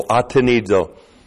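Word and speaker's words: Atenizo (ἀτενίζω): The Greek is atenizo. Looks like that in the Greek. A-T-E-N-I-Z. Long O. Atenizo.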